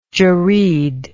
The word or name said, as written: jerreed